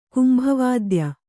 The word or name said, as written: ಕುಂಭವಾದ್ಯ